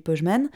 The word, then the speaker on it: Pojzman: C'est souvent un nom sur lequel euh, les gens butent parce que ça s'écrit P-O-J-Z-M-A-N, donc il y a quand même trois consonnes l'une après l'autre. Euh, donc les gens savent pas trop comment le prononcer, et en plus, moi en école maternelle, je pense en dernière section, j'ai pas des souvenirs ultra précis.